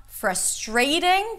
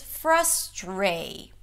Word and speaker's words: frustrating: So it's not frustrating. The second syllable is not stressed. It's not frustrating. It's not an A sound. It's frustray. Tray.